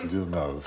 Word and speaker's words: Villeneuve: Pretty sure he's French and it's pronounced Villeneuve.